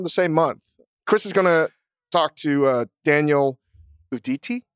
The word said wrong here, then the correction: Daniele Uditi: Daniele Uditi from Pizzana here in LA, who's the head judge of Best in Dough.